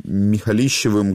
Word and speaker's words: Михалищевым: Обо всем этом с Данилой Михалищевым, кредитным юристом. Добрый вечер, Данила. Я надеюсь, я не перепутал ударение. – Да, добрый вечер, все верно.